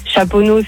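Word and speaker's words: Chaponost: Chaponneau, c'est ST, tu le prononces pas.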